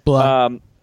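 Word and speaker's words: Blough: I do think that David Blah or Blow or however you say his, how do you say his name? – Blah.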